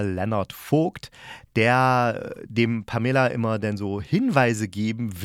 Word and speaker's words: Pamela: Und ja, sie wird tatsächlich Pamela ausgesprochen und nicht Pamela, obwohl sie nach einer Figur aus Dallas, einer Pamela Blablabla bla bla benannt wurde.